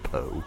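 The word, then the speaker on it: Pou: and I think it's pronounced Poe.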